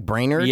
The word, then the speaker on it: Brainerd: It's like brain then ERD, like Brainerd? – Yeah. – Okay. – The movie is called what it is today, obviously. – Yeah. Because it sounded better than Brainerd? – The producers said it sounded better than Brainerd.